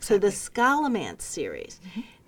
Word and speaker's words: Scholomance: And there you came in and said, oh, the latest in the Golden Enclaves series, Scholomance. Is that how you pronounce that? – I think Scholomance. – Scholomance. Mm-hmm. So this series... S-C-H-O-L-O-M-A-N-C-E.